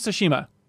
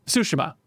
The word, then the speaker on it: Tsushima: Ghost of Tsushima. I'm sorry, Tsushima. I got to get used to pronouncing that right.